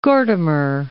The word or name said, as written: Gordimer